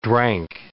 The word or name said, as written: drank